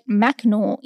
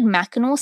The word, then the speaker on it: Mackinac: The island's name was shortened from Michilimackinac to just straight Mackinac in the 10th century. And it wasn't until 1857 when a Mr. Edgar Conkling founded Mackinac City on the mainland, where he changed the spelling, replacing the silent C with an actual AW.